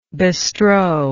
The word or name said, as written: bestrow